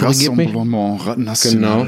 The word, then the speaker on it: Rassemblement National: Rassemblement National.